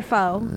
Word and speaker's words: faux: And, all my rugs are faux. You know? Is that how you say it? Faux? – Yeah. – I said fox earlier. No one caught on to it.